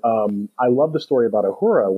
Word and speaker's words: Uhura: Uhura, is that how you say it? – Uhura and Sulu.